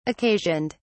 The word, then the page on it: occasioned: British English